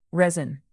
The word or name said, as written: resin